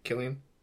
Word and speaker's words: Cillian: Cillian? – Yeah, Cillian.